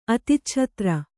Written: ಅತಿಚ್ಛತ್ರೆ

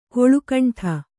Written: ಕಳ್ಳಕಿಂಡಿ

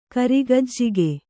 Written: ಕರಿಗಜ್ಜಿಗೆ

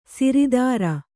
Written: ಸಿರಿದರ

